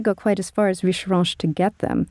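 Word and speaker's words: Richerenches: It's called Richeranche, and I'll spell that. It's R-I-C-H-E-R-E-N-C-H-E-S.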